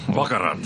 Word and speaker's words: baccarat: Isn't it pronounced baccarat? – Baccarat. – Oh, whatever. Sorry, yes. Baccarat.